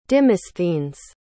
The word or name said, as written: dimosthenes